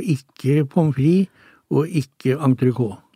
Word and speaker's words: entrecôte: Hvis vi beveger oss over på middagstallerkenen, for eksempel, en elegant fransk kjøttrett som mange uttaler entrecôte, er det riktig? – Nei, her sier vi entrecôte, og vi spiser det jo helst med pommes frites.